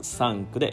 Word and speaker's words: sank: 過去形がサンクで SANK まあ、ちょっと今発音悪かったですけど、まあ、ちゃんと発音してないですね。えっ、ー、と、キャットの A ですね。あいうえおの A の口で、えー、あっていう、サンクっていう、えっ、ー、と、 SNK が過去形。